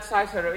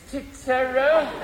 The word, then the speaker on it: Cicero: P-L-A-T-O. The next was Cicero, an old Roman. I write it on the blackboard. It's pronounced Cicero, not Cicero. It's Cicero.